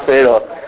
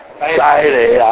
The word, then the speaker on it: 西螺: 我当阵咧分享的时候，我讲哦，这个叫做西螺吼。对啦，你看嘛，啊，你看阮看台，阮看迄个花样啊，西螺就西西螺啊，西螺，还是正确发音的啦，西螺啦吼。